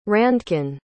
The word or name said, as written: Randkin